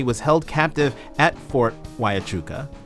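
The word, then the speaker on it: Huachuca: Wyachuca. – Fort Wyachuca in Florence, Arizona, 70 miles outside of Tucson.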